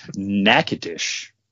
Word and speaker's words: Natchitoches: So I've been just pronouncing it wrong all my life. Yeah, thank you for the correction. Natchitoches.